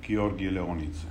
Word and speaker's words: Giorgi Leonidze: Georgi Leonidze.